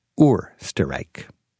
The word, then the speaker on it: Österreich: When an E follows the O, it works like an umlaut, giving the O its correct pronunciation, Österreich.